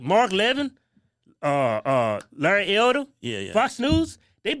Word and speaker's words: Levin: Levin is Levin.